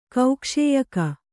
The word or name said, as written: ಕೌಕ್ಷೇಯಕ